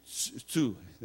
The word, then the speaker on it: Tsu: In Tsu, T-S-U, Tsu, the Japanese pronounce it, Tsu, to Japan.